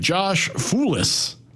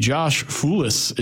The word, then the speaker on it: Foulis: I didn't think I was going to have to say his name, but Josh Foulis, I believe, is the pronunciation. F-O-U-L-I-S.